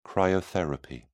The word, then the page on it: cryotherapy: British English